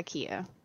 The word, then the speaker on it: Killa: Kia Chandra, but do you go by K-I-A or do you go by K-I-L-L-A? – Okay, so the way it's pronounced is kind of like, you know how tortilla is? – Yes. – It's Spanish word, so you pronounce it without the L's. So, I mean, technically, the way you would pronounce it is K-I-A. It's Kia. – Uh-huh. – So you just, the L's are silent. So, but your full name, is it K-I-L-L-A? – Mm-hmm.